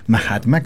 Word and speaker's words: Mahatma: Mahatma, como gran alma, Mahatma. – Sí. Ya, Mahatma, pero se escribe... – M-A-H-A-T-M. – ADM, Mahatma. Perfecto, Mahatma, ya, y Paula.